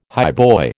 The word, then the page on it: highboy: American English